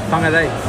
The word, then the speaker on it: Whangarei: Whangarei. – How do your parents pronounce it? – Whangarei.